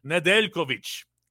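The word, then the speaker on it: Nedeljković: Alexander Nedeljkovic is how you would say it exactly.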